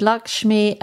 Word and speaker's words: Lakshmi: And it says Lakshmi pronounced lock, L O C K S C H M E E. So it's, even though it's spelled, it looks like Lakshmi, it's actually pronounced Lakshmi, is the Hindu goddess of good fortune and beauty.